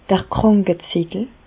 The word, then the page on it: d'r Krànkezettel: Bas Rhin; Reichshoffen